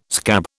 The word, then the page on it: scab: British English